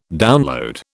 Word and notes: download: British English

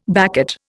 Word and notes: baccate: Indian

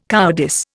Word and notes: cowardice: Indian

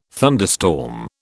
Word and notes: thunderstorm: British English

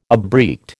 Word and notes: abreact: American English

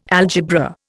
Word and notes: algebra: Indian

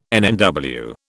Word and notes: NNW: British English

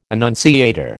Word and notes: annunciator: American English

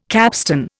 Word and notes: capstan: Indian

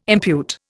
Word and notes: impute: Indian

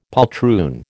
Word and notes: poltroon: American English

